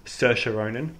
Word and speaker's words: Saoirse Ronan: It's a coming-of-age story starring Saoirse Ronan, pronounced correctly, by the way.